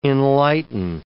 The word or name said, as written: enlighten